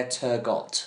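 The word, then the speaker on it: Turgott: Love the seesaw pronunciation of Turgot and Turgo. Well, one has to be right. It's actually got. So it's Blair Turgot.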